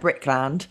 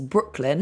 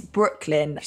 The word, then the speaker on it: Brooklyn: The film that I mentioned last week, which sounded like Brickland, is Brooklyn, the place in... Yeah, I can only apologise for pronunciation there. – Non-Irish accent. – Yes. So it's Brooklyn.